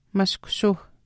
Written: mashkushuuh

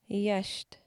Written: iiyaashiht

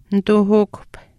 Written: nituuhukuhp

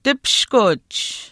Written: tipishkuch

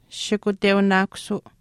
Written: shikuteunaakusuu

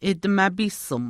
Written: atimaapiisim

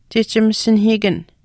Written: chihchimasinahiikan

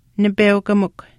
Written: nipeukamikw

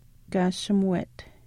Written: kaashimuwet

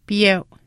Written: piyeu

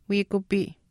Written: wiikupii

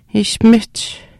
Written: ishpimihch

